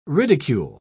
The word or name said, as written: ridicule